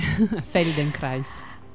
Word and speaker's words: Feldenkrais: Feldenkrais. L'ho detto bene? – Sì, hai detto bene. Feldenkrais.